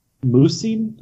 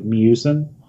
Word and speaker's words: Miucin: Philip Musin, M-I-U-C-I-N. So it's like Musin. – Okay. – I think it's Musin.